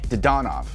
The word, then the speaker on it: Dadonov: The emphasis on Russian names is in the middle syllable. – Dadonov. – Dadonov.